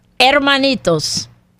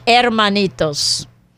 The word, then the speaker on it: hermanitos: Hermanitos. – Hermanitos. – The H is silent in Spanish. Hermanitos.